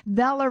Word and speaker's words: Vela: Vela is in rhymes with Bella. It's Vela rhymes with Bella.